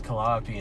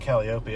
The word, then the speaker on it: Calliope: I had a buddy in the camp that said Calliope, and it blew everyone in our camp's mind. – Yeah. – She said that. – That's actually the proper way to say it.